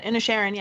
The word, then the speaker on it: Inisherin: Because Banshees of Inisherin, is that how you say it? – Mm-hmm. – Whatever. – Yeah, Inisherin, yeah.